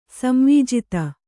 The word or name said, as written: ಸಂವೀಜಿತ